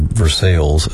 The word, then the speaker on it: Versailles: And due to my father's job, during childhood, we had to move to Central Kentucky and settled in a little town called Versailles, as the locals say.